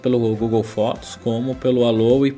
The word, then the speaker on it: Allo: entre elas dois novos aplicativos de comunicação um chamado Alo ou Alô, não sei qual é a pronúncia certa. Como é que é, Breno? Alô mesmo. Alô mesmo? – Tipo é. em português. É, tipo em português.